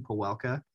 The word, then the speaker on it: Pawelka: I um, it actually comes from my last name, Pawelka, P A W E L K A.